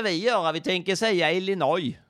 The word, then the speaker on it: Illinois: Illinois. You know what? You know how I know? It's because the S is silent.